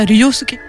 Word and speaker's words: Ryusuke: Est-ce qu'on est bon au niveau de la prononciation? – Presque. – Ok, vas-y, tu le sais, comment on dit? – Bah, Ryusuke. – Ryusuke. Ryusuke Hamaguchi.